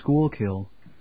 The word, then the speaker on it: Schuylkill: Okay, basically, the name of the facility is Schuylkill, which is a place apparently in Pennsylvania.